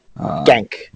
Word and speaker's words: Genk: Yeah, Liverpool's past three games beat Arsenal on penalties, barely beat Aston Villa, and barely beat Genk.